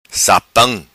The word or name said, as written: Sapam